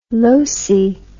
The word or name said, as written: losey